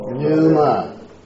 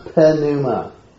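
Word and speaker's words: pneuma: So it's 2 syllables. Numa. Numa. It's not penuma. It's numa.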